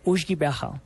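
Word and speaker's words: uisge beatha: Agua de vida en gaélico, en el gaélico se pronuncia ushgibeha y el Ushki Beaja fue cambiando a Ushki, después a Ishki y finalmente terminó llamándose whisky.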